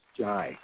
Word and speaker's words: Jai: My name is Jai, J-A-I, pronounced J-Y, not Jai.